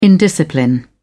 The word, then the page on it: indiscipline: British English